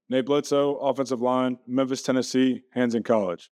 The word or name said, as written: Nate Bledsoe